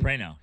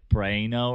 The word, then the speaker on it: Prano: No, I mean, I think it's technically got a little bit more Italian, like Prano or something like that.